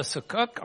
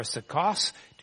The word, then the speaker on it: Sukkot: It was called the Sukkot or Sukkos, depending on how you pronounce it and how you read it, the Festival of Booths.